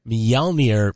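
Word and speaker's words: Mjölnir: Says a buddy of mine brought a Mjolnir pendant back from a work trip in Norway.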